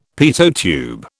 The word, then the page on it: Pitot tube: British English